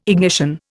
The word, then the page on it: ignition: Indian